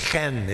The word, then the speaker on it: chen: The Hebrew word is chen. It's kind of hard for us to pronounce because it starts with kind of almost a guttural sound in the throat. But it transliterated, it's C-H-E-N, but it's a hard C-H, not a k, but more of a ch.